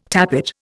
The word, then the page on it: tappet: Indian